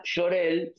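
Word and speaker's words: Jor-El: Cambia el nombre porque en realidad Jorel se escribía Jor L y la pronunciación es Jor el Cuando alguien fue y lo escribió en el, en el guión, le puso la E y ahí quedó con la E.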